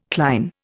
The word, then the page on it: cline: Indian